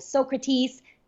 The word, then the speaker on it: Socrates: And we start to see the rise of schools of the school of Socrates. Did I say it correctly? – Yes.